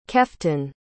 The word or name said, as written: keften